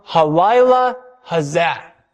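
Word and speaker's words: Halayla hazah (הלילה הזה): Halayla hazah. That's Hebrew for this night. It literally says the night the this, because that's how you say it in Hebrew.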